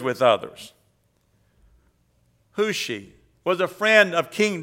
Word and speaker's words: Hushai: Hushi. The last one I shared with you, Hushi. You pronounce it like W-H-O-S-H-I, but it is written H-U-S-H-A-I.